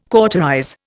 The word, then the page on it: cauterize: Indian